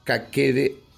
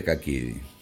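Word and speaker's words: kakete: En cuanto al término caquete, según Motobu Naoki Shihan del Motobu Ryu, la pronunciación es kakede en lugar de kakiri.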